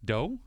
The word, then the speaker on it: doux: What, how do you say D-O-U-X? Doe? – Do? – Do? – Do South. – It is do.